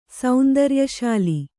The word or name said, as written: ಸೌಂದರ್ಯಶಾಲಿ